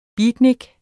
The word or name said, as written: beatnik